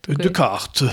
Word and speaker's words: Descartes: Descartes.